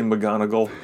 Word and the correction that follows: Monongahela: That's not right. – Monongahela.